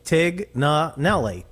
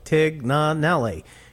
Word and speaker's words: Tignanelli: Well, you know, everybody looks at my name and tries to pronounce it some way, you know, that, and it's pronounced phonetically, Tignanelli, Tignanelli. You're Lavazza, L-A-V-E-Z-Z-A.